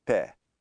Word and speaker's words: pé: Pé. É, with that é sound.